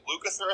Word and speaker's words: Lukather: Yeah, I was gonna say that I think Colin Hay and the guy from, is it Lukather, is that how you say his name? – Yes.